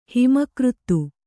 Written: ಹಿಮಕೃತ್ತು

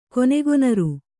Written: ಕೊನೆಗೊನರು

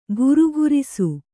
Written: ಘರ್ಘರಿಸು

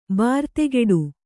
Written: ಬರ್ದುಗೆಡು

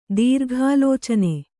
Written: ದೀರ್ಘಾಲೋಚನೆ

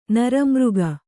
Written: ನರಮೃಗ

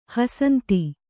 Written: ಹಸಂತಿ

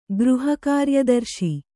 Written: ಗೃಹಕಾರ್ಯದರ್ಶಿ

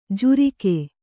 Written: ಝುರಿಕೆ

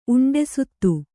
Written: ಉಂಡೆಸುತ್ತು

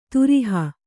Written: ದ್ರೋಹಿ

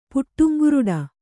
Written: ಪುಟ್ಟುಂಗುರುಡ

